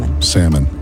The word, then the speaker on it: salmon: Salmon. The L is silent. – Okay. – So like the P in pterodactyl.